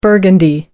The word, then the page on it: burgundy: American English